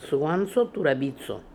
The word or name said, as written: Sooanso Toorabizo